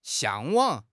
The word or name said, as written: 向往